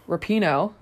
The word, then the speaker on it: Rapinoe: One of the female players, Megan Rapinoe.